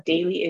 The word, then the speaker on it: Daley: Daily is spelled D-A-L-E-Y.